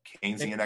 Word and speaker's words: Keynesian: Like this can, I always mispronounce it. Keynesian economics is that is can Keynesian economics. Keynesian economics, yeah.